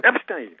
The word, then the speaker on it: Epstein: Epstein.